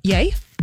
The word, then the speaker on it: Ye: Ye? – Yeah.